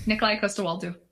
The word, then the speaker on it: Nikolaj Coster-Waldau: Nikolai Costa Waldo. – Okay, Nikolai Costa Waldo.